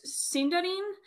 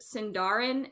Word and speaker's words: Sindarin: Sindarin, which a lot of people pronounce it Sindarin. It is not. It's Sindarin because it's E.